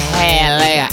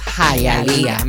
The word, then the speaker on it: Hialeah: No es Hialeah, es Hialeah. Me la pronuncian bien.